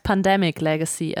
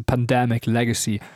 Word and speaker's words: Pandemic Legacy: Muss ich nur einmal kurz korrigieren, das heißt Pandemic Legacy. Also. – Oh, mein Englisch ist scheiße. Pandemic Legacy.